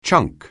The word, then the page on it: chunk: anglais